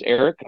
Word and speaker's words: Arek: And my name's Eric. I'm kind of weird. I spell it A-R-E-K.